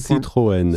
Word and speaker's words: Citroën: Citroën. – Citroën, yes. But I've always heard of like a little bit of, I'll try my French. I took it in seventh grade. And the Renaults. But the Citroëns Peugeot.